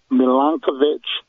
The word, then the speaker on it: Milankovitch: Milankovitch cycles. – Spell it. – Ice age cycles. – Please. – Milankovitch.